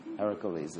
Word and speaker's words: Heracles: Heracles is the way you pronounce it.